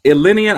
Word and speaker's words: Illini: You said it right first time. Illini.